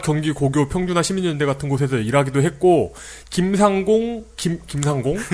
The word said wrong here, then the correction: 김상곤: 김상곤 예.